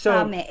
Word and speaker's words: Pame: Correct me if I'm not pronouncing it right, but is it PAME? – Yes, Pame. It's a Greek word that means let's go together.